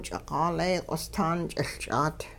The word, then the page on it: ch'iqaalee q'ostaan ch'iLch'aat: Hupa